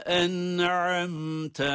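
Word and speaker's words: En'amte (أنعمت): Sıratallezine en'amte aleyhim. En'amte. Hem nunun okunuşunda hem mimin okunuşunda cezimli olmaları na rağmen, hatta izhar olmalarına rağmen hunne sesini duyurmalıyız. Bakınız. En'amte aleyhim. Bunlar hunnenin sesidir.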